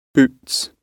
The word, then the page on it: boots: British English